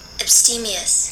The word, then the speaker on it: abstemious: One who is abstemious, abstains from certain pleasures. – Wait. – Abstemious. – Hey. – Abstemious. I was right the first time. – It's a long E.